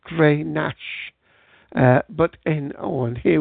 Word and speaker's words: Grenache: Okay, so it's pronounced Grenache, and it's one of the most widely planted red grape varieties in the world.